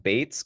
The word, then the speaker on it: Beetz: I guess it's pronounced Bates, kind of like Beethoven, because she's German.